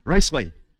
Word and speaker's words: Ruisui: I don't know if I'm pronouncing it right, but Ruisui, is that how we pronounce the word? Ruisui? I got it right? All right. Huh? Fourth tone. Ruisui. Is that right?